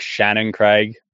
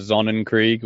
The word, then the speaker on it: Sonnenkrieg: He called it like Shannonkrieg Division instead of Sonnenkrieg, which obviously a German word.